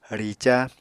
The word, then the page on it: rica: Apatani